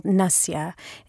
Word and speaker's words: nasya: One thing that we can do at home that is a very simple Ayurvedic practice is something called nasya. It's spelled N-A-S-Y-A, and it's a simple application of oil to the nose.